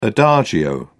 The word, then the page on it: adagio: British English